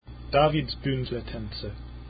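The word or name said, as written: Davidsbündlertänze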